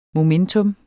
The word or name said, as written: MOMENTUM